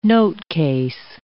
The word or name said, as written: notecase